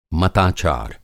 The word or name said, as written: मताचार